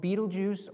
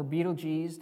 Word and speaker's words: Betelgeuse: There's the star that's called Betelgeuse or Betelgeuse, depending on how you want to pronounce it.